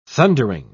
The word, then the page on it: thundering: British English